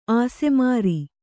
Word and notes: ಆಸೆಮಾರಿ: āsemāri

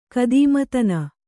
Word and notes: ಖದೀಮತನ: khadīmatana

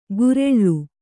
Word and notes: ಗುರೆಳ್ಳು: gureḷḷu; kannada

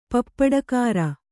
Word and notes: ಪಪ್ಪಡಕಾರ: kannada

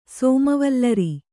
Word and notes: ಸೋಮವಲ್ಲರಿ: sōma vallari

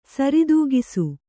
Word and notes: ಸರಿತೂಗಿಸು: sari tūgisu

